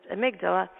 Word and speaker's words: amygdala: And I said, so the amygdala, which, by the way, is pronounced amygdala, and he looked at me, and I said, I'm Canadian.